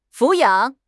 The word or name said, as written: 抚养